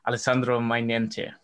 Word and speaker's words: Alessandro Mainente: I'm happy to welcome you from Italy, Alessandro Mainente.